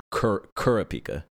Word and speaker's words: Kurapika: You're supposed to put the okay. – accent on the first vowel in Japanese. So I think it would be, was it Kurapika? Is how you would probably properly pronounce it, but I default to my American brain for his name.